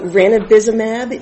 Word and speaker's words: ranibizumab: So ranibizumab is.